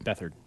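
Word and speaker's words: Beathard: Bethard. CJ Bethard. – Have you seen the memes where it's like the Miami Dolphins played the 49ers and the 49ers got, and then they show Bethard's name and it's like beat hard.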